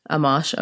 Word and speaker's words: Amash: Amash.